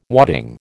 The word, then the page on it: wadding: American English